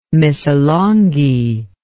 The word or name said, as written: Missolonghi